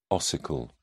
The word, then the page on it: ossicle: British English